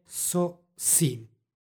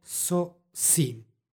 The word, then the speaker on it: sosim: Izgovor je sosim. Neka nas Gospod umudri. Instrumental u stvari ima ulogu socijativa, pokazuje društvo u kome se vrši radnja. Izgovor je sosim.